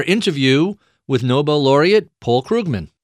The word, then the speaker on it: Krugman: And when Krugman is the correct way to pronounce it,